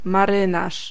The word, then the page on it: marynarz: /maˈrɨnaʂ/